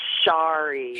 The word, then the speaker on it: Charie: Charie. – Huh, that's not what I see up on the board. – It's like Charlie without the L.